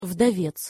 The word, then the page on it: vdovec: Russian